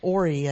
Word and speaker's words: Ori: Ori, I think, is how you pronounce this, O-R-I, is this single large cabinet, and it has lots of stuff.